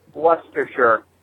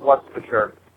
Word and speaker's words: Leicestershire: The town that looks like it would be pronounced Leicestershire is pronounced Leicestershire. That's how you would pronounce that word, Leicestershire.